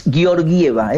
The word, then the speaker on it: Georgieva: Alguien me corrigió, es Georgieva, es difícil de pronunciar, Georgieva, le decíamos nosotros, no.